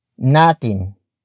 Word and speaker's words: natin: The next word is natin. Natin. Natin. Natin is spelled as N-A-T-I-N.